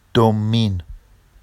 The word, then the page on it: dommin: Vafsi